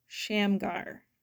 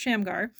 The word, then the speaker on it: Shamgar: And I believe today's character's name is pronounced Shamgar. Now, I'm going to let that debate amongst yourselves or just wait for Michael to pronounce it here in a second. But I believe it's pronounced Shamgar. So,